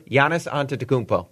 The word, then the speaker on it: Antetokounmpo: Giannis Antetokounmpo.